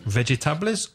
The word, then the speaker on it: vegetables: The very first thing is, so many Spanish speakers mispronounce this word in English. Vegetables. – How many syllables? – Vegetables. Three syllables. – And which one is stressed from those three syllables? Which syllable is the strongest? Más fuerte. – The first one.